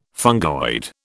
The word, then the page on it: fungoid: British English